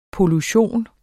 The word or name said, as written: pollution